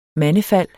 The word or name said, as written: mandefald